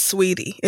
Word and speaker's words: Saweetie: Is it Saweetie or Sweetie? – It's pronounced Sweetie. It's spelled Saweetie.